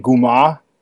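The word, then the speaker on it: goomah: The first book you mentioned, though, when we first talked was Kamar. Am I pronouncing that right? – Yeah, actually you are, but since I'm from New York, we say it like Tony Soprano would in Guma, which is where we actually got the title for it.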